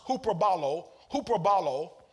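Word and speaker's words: huperballo: All right, now, that word, when it says in 2 Corinthians 9:14, exceeding grace, that word exceeding I gave to you, actually it's pronounced huperbalo, which means to surpass in throwing, to throw over or beyond anything.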